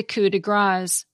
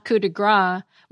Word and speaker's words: coup de grâce: And the finishing touch on a job is the coup de grâce, because grâce is spelled G-R-A-C-E. It's not coup de gras, which literally means a strike of fat.